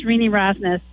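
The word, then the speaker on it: Renee Rosnes: Uh, it's, it's Rini. It's pronounced Rini Rosnes.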